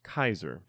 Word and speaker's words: Caesar: And since there is no soft C sound in Latin, Caesar is pronounced Kaiser.